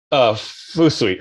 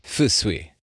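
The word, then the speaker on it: Fusui (風水): One more time on the pronunciation. – Fusui. – Fusui. Awesome. – Yeah. Wind is fu. And then sui is water.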